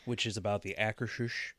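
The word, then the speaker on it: Akershus: I think it's Akershish. Yeah, it's just Akershish.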